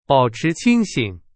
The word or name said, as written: 清醒